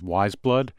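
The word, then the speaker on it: Weyes Blood: It's by an artist known as Wiseblood. It's spelled W-E-Y-E-S, like the word eyes with the W in front of it.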